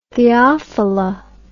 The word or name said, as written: theophila